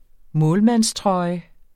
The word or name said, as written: målmandstrøje